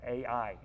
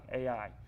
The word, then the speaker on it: Ai: As a matter of fact, if you go one chapter over, a little bitty town named Ai, two letters, Ai. That's a great name. That's how you pronounce that word, Ai, Ai.